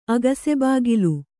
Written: ಅಗಸೆಬಾಗಿಲ್

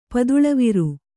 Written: ಪದುಳವಿರು

